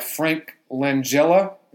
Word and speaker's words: Langella: Great performances by Frank Langella.